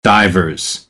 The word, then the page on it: divers: American English; ɚ - ə